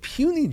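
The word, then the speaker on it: puisne: And the eight puny judges, $373,900 each. Puny is actually how you pronounce that word.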